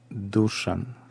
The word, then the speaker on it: Душан: Ударение на Душан.